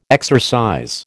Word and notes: exercise: American English

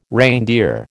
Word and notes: reindeer: American English

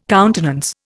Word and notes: countenance: Indian

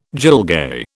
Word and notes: gilgai: British English

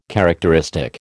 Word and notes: characteristic: American English

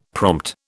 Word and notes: prompt: British English

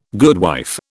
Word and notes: goodwife: British English

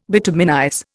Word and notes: bituminize: Indian

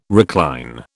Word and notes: recline: British English

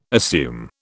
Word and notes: assume: British English

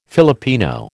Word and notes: Filipino: American English

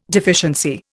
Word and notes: deficiency: Indian

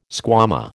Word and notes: squama: American English

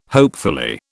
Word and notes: hopefully: British English

